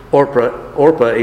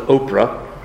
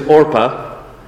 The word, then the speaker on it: Orpah: There was still a longing to serve the Lord to such a degree that Ruth, at least, if not Orpah, and it is Orpah, even though some people will pronounce it Oprah, it is Orpah.